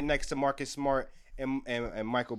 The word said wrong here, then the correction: Mikal: Bridges, Mikal Bridges. How you pronounce it? Mikal. – You say it right, Mikal.